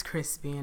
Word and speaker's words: Chrispy: And my name is Crispy, and that's spelled C H R I S P.